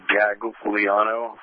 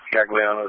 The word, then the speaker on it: Gagliano: Last name, Gagliano. First name, Renee. Gagliano is spelled George, Adam, George, Lincoln, Ida, Adam, Nora, Ocean.